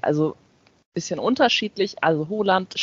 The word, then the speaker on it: Haaland: Erling Haaland. – Haaland, ganz einfach eigentlich, ja, mit genau, OH ganz sozusagen. Einfach. Ja, das genau, das wird wie O ausgesprochen und der Vorname halt auch nicht Erling, sondern Erling.